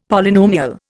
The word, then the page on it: Polynomial: Indian